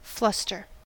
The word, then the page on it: fluster: American English